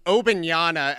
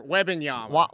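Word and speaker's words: Wembanyama: What are you doing getting Obenyana as the pronunciation in my head for Webenyama? – Now I can't even say it.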